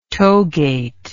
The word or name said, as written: togate